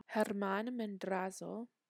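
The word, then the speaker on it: Germán Madrazo: Editing note, I did not say it right the first time, so I'm re-recording it right now. Germán Mendrazo.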